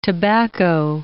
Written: tobacco

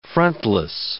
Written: frontless

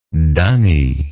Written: dunny

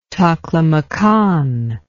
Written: taklamakan

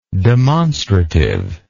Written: demonstrativeness

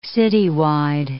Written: citywide

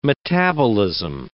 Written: metabolism

